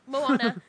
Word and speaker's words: Moana: I'm sorry, but I keep pronouncing it Mona. – Moana. – I know. I can't believe. Like, I mean, it makes sense. But the Hawaiian language has a certain way of pronunciation.